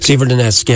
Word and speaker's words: Severodonetsk: Severodonetsk, is that they pronounce it? – Severodonetsk. – Severodonetsk, yeah.